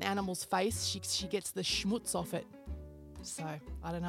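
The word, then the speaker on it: Schmootz: Schmutz. There's no chance I've mispronounced this because it's S-C-H-M-O-O-T-Z.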